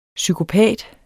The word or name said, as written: psykopat